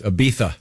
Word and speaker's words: Ibiza: She did it in Ibiza. – Oh, nice. – Huh. – It's Ibiza. – It's Ibiza. Ibiza, by the way. – Come on with that. – The Z is pronounced TH.